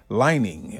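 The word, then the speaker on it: Li-Ning: 10-year sneaker deal with the Chinese sportswear giant Lining.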